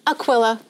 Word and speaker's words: Aquila: Aquila.